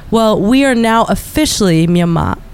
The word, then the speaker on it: Myanmar: And what a lot of European language speakers don't hear is that the Burmese pronunciation of the country is actually Myanmar.